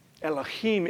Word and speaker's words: Elohim: Okay, the first Hebrew words, Bereshit bara Elohim. Elohim is the creator God. Okay, that's how you pronounce that.